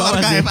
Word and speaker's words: archive: Ini bacanya arsip kan, arsip. – Arsive? – Aduh. – Bener gak? – Bener gak? Bener gak? – Bener gak teman-teman? – Salah. – Salah. – Yang bener apa dong? – Iya bener sih. – Apa tuh archive? Ada sih, tulisannya sih bener sih. – Archive, yes. Oke.